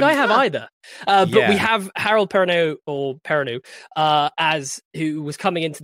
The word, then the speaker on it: Perrineau: I can never pronounce his last name. – Harold Perrineau. – Perrineau. That's it. Perrineau.